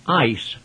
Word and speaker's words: eis: a Greek preposition pronounced eis. It's spelled E-I-S.